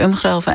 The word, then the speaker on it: Umbgrove: Arthur Umgrove. En ik ga je naam nooit meer verkeerd uitspreken. – Het ging helemaal goed.